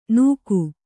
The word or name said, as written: ನೂಕು